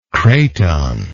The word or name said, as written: craton